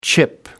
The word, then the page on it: chip in: American English